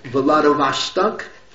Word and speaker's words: Vladivostok: You're going to go travel from Vilna to Moscow, take the train to Vladivostok. How do you pronounce it? – Vladivostok, thank you.